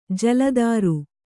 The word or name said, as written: ಜಲಾಧಾರ